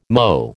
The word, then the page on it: MOT: American English